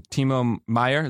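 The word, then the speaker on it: Timo Meier: Timo Meyer, is that how you say it? – Yep.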